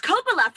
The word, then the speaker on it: Coppola: Oh, that's Coppola, Francis Ford Coppola's wine.